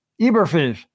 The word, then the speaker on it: Eberflus: Eberflus.